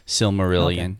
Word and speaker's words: Silmarillion: He threw in a metric shit ton of stuff from Silmarillion, which is that how you say it? – Silmarillion.